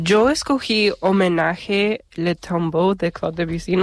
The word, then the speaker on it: Le Tombeau: Bueno, lo has pronunciado bastante bien. – Vale. – Yo tampoco soy francés, pero Le Tombeau de Claude Debussy.